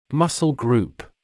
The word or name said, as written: muscle group